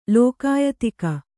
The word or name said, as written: ಲೋಕಾಯತಿಕ